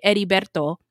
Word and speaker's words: Heriberto: So Heriberto, H in Spanish is silent. So when you say Heriberto, it sounds kind of like Eddie.